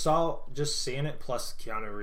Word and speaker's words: Keanu: Yeah, Keanu. – Anyway. – It's pronounced it's like K-I and then Anu. – Keanu. – Key, like the Dragon Ball Key.